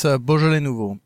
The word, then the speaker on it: Beaujolais Nouveau: I am drinking a glass of, YouTube tells me to pronounce it like this, Beaujolais Nouveau wine. Did I get that right? – Yes, it's Beaujolais Nouveau.